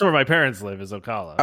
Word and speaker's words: Ocala: But it's going to be, how do you pronounce that? Oscala? It was by Tampa, whatever that is. – Are you talking about Ocala? – Ocala, yeah, or, sure.